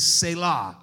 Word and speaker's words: Selah: So I'm going to go with what I see on the Hebrew pronunciation, which is Selah. I like that part. You're supposed to put emphasis on the latter part, not the first part.